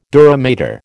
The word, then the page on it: dura mater: American English